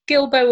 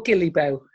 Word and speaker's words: Gilbo: It looks like it should be pronounced differently, so I'm glad I did look at the videos. So Gilbo or Gilibo seems to be the way to pronounce it.